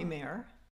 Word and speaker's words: Mare: People call me Mare. – It has one or three syllables, depending on how you say it. Mare. Like you can have like a Mare or Mare.